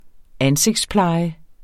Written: ansigtspleje

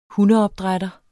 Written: hundeopdrætter

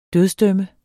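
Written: dødsdøm

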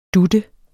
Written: dut

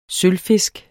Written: sølvfisk

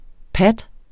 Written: pat

